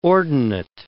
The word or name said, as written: ordinate